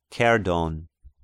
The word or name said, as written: Kerdon